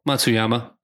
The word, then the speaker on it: Matsuyama: Matsuyama.